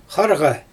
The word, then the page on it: xarɣa: Xyzyl